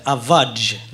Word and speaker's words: Avage: Jag måste bara det är svårt att säga, men det heter Avaj när man uttalar Avaj!